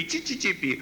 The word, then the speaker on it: СССР: чи-чи-чи-пи». Оказалось, по-итальянски СССР произносится как «чи-чи-чи-пи».